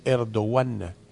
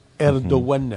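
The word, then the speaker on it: Erdoğan: Se dice Erdogan. Erdogan. Uh-huh. La G y la N final no tienen sonido.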